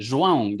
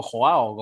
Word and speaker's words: João: João, yo te digo João, no te digo Joao, como, como pronunciarían el nombre Joao mm.